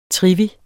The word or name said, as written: trivi